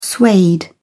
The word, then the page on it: suede: British English